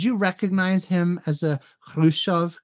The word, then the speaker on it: Khrushchev: Well, I think the standard English transliteration or the way of writing it is Khrushchev. However, in Russian, it should be pronounced Khrushchev.